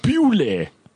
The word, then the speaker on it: Beaulieu: Or Bule, which is spelled B-E-A-U-L-I-E-U. There's a suburb in Forwell, in sort of Midrand, called Bule.